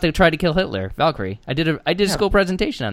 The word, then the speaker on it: Valkyrie: Valkyrie. Come on, bud. – That's it. Yep. That's how you say it.